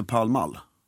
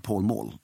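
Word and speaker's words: Pall Mall: Varför heter, men för den stavas Paul, P-A-L-L. – P-A-L-L. – M-A-L-L. – Ja. – Och engelsmännen säger, we are going to Palmall. – Okej. – Okay. De säger inte, we are going to Paul Mall, utan bara så man vet det med uttalet.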